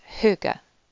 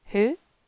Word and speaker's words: hygge: So quite often mispronounced or thought of as the latest trend in well-being. But it's pronounced hygge. Hygge. Hygge. It's a Danish word.